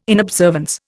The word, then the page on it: inobservance: Indian